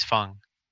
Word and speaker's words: Feng: Wong Fang, sorry. I'm saying Wang like an American. – Fang is not Fang. It's Fung, right? F-E-N-G is Fung. It's pronounced Fung.